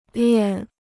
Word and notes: 练: liàn

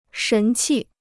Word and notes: 神气: shén qì

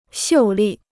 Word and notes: 秀丽: xiù lì

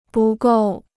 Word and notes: 不够: bù gòu

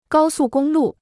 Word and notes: 高速公路: gāo sù gōng lù

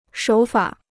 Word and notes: 手法: shǒu fǎ

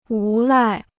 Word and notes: 无赖: wú lài

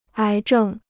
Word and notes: 癌症: ái zhèng

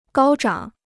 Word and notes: 高涨: gāo zhǎng

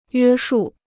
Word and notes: 约束: yuē shù

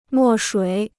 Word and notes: 墨水: mò shuǐ

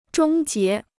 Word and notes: 终结: zhōng jié

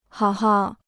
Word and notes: 好好: hǎo hǎo

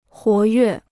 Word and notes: 活跃: huó yuè

